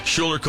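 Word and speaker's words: Schuyler: All right, Colfax, California is named after, I think his first name is pronounced Shuler or Shiler. Is it Shuler? – Yeah. – Shuler Colfax.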